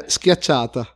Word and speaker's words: schiacciata: Garlic and parmigiana sciarra? – Yeah. In the last 1 year, only 3 persons could say it correctly. So. It's a schiacciata.